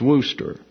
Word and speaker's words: Worcester: And then the form is also written as Kester, C-E-S-T-E-R, which only the S-T-E-R is pronounced. For example, you have what would appear to be Worcester, W-R-C-E-S-T-E-R, and it's pronounced Worcester.